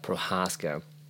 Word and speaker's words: Prochazka: Prochazka.